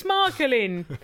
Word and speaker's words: Michelin: I am going to be saying Michelin, for the rest of this because I'm English. – Michelin. – Michelin, innit? – It's Michael in.